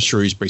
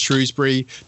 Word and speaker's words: Shrewsbury: I used to have a cafe in Shrewsbury, and there's actually three pronunciations of Shrewsbury. So it looks like it's pronounced Shrewsbury. The BBC pronounce it Shrewsbury.